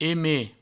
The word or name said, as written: eme